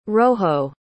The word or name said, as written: roho